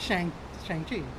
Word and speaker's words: Shang-Chi: Shang-Chi. No, because it's funny, because my reference was Mortal Kombat, yeah. Shang Tsung. – Shang Tsung, yeah. – It was like Shang-Chi.